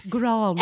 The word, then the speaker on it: Grande: Pronounce the E. – Grande.